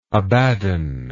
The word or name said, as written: Abaddon